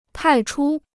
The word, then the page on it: 派出: pài chū